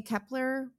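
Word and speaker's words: Kep1er: And I think you say Kepler. It's a one, looks like an L. I believe that's how you pronounce it.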